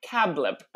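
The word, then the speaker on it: CABLP: It's spelled cab-L-P, but it's pronounced cablip.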